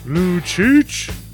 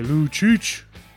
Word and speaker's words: Lucic: And that's how I say his name every single time he has the puck on the ice. And hopefully now you guys will as well. I don't just say Lou. I say Lou Cheech.